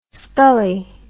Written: Scully

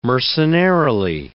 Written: mercenarily

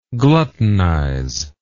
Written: gluttonize

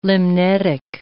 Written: limnetic

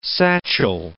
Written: satchel

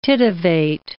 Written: titivate

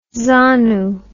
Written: zanu

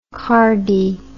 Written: cardi

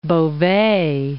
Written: Beauvais

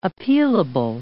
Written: appealable

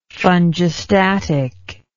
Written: fungistatically

